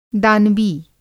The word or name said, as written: दानवी